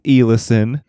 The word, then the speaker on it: Eliason: It's Eliason. I know because her grandpa or her husband's grandpa did heritage tours. Anyone that went to American Fork knows who that is. So that's how I know how to say the name.